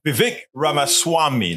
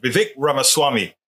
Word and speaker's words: Vivek Ramaswamy: I can never say his name. Vivek Ramaswamy. That's how he says it. Vivek Ramaswamy.